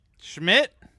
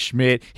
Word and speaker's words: Schmidt: His name rhymes with Pitt. – Schmidt? – Mike Schmidt.